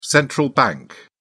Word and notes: central bank: British English

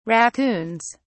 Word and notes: raccoons: British English

